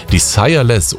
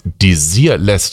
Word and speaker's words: Desireless: schöne Grüße rüber ins Franzosenviertel nach Haidhausen mit dieser Nummer von Desireless oder von Desireless. Da sind beide Aussprachen tatsächlich richtig und gültig.